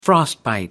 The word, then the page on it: frostbite: American English